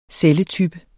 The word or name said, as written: celletype